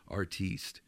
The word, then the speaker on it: artiste: So we're dealing with an artiste, capital A, silent E, artiste here.